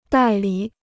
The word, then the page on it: 代理: dài lǐ